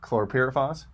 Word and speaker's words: chlorpyrifos: And so Brenda came this session specifically to talk about chlorpyrifos.